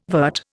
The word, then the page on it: vert: Indian